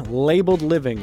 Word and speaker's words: LBLD Living: That's LBLD Living. Labeled Living is how you pronounce it.